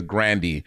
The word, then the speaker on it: Grande: Her last name is not Grande, it's Grandy.